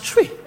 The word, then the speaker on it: Twi: It's spelled T-W-I, but it's pronounced Chui.